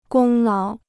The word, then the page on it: 功劳: gōng láo